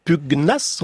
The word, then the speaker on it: pugnace: Pugnace. On prononce bien le G.